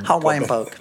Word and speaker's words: Hawaiian poke: Det kanske heter Hawaiian poke. Det är så, va? Hawaiian poke. Ja, jag sa nog Hawaii poke. – Folk kommer rasa för det uttal. – Hawaiian poke.